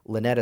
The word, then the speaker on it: Linett: Lynette is spelled with one N and two T's. No E at the end. L-I-N-E-T-T.